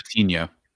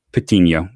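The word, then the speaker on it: Patiño: And if you're comparing centerpieces, I mean, I still think Patino blows him away, and I'm probably butchering his name again. – I think it's Patino. – Patino. Makes sense. There's the Enya, right? – Yeah.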